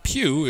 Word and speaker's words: Pugh: Vogt is spelled V-O-G-T, and Pugh is spelled P-U-G-H. So not said the way they look.